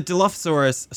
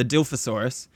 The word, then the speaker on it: Dilophosaurus: Well, if the Dilophosaurus, sorry, the Dilophosaurus.